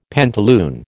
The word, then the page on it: pantaloon: American English